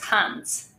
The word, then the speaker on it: Hanc: It's Dominica, and my surname is Hanc. – Hanc. Dominica, yeah. Hanc. I like that. – Thank you. Yeah. I'm originally from Poland, so that's why the pronunciation is a bit different, I guess.